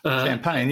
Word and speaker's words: Champaign: I hope I'm pronouncing that correctly. – Champaign, yeah, I think you got that right.